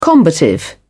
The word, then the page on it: combative: British English